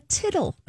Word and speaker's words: tittle: Except it's called a tittle. – A tittle. – Oh, it's a tittle, it's not a title.